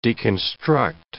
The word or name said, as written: deconstruct